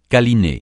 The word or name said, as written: câliner